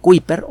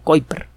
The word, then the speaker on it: Kuiper: Bueno, este cinturón de núcleos cometarios se le conoce como el cinturón de Kuiper o Kuiper, como lo quiere usted pronunciar, se escribe K-U-I-P-E-R.